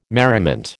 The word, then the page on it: merriment: American English